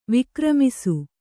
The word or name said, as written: ವಿಕ್ರಮಿಸು